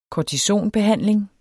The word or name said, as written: kortisonbehandling